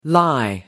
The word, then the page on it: lie in: American English